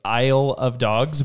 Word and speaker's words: Isle of Dogs: Like it's Isle of Dogs because it's a play on words of I love dogs.